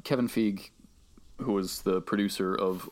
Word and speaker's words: Feige: Fun fact, I think I saw an interview yesterday where they said that his last name is pronounced Feige.